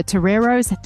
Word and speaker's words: Toreros: I read that you are now entering your fifth year of coaching at USD, that you've coached the Toreros. Did I pronounce that correctly? – Spot on. Yeah.